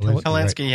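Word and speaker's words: Hilinski: Am I pronouncing that correct? – Helinski, yep.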